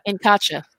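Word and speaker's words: Incacha: N'Chaka? – N'Chaka, yeah. – And what N'Chaka did for him in South America. Like, what was going on there? Oh, what N'Kacha. N'Kacha. We're inverting the syllables.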